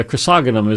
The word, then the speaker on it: chrysogonum: Uh, chrysogonum is the way to pronounce it.